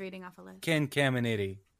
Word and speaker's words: Caminiti: Jason and Jeremy Giambi, Ken Camentini, Ken Caminetti. Caminetti, is that how you say his name? – Oh, you just add an extra T.